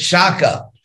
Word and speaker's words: Shaka: All those places in Central Asia, some of those people invaded India, and they were called in English, you say, Scythians. In Indian languages, they pronounced it Shaka.